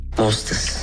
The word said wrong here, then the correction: mortaise: Non, mortaise.